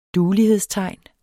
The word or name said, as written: duelighedstegn